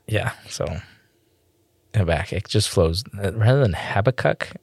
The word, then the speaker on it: Habakkuk: I've always pronounced it Habakkuk correctly. – Most Hebrew people have.